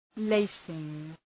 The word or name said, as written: lacing